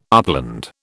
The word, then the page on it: upland: British English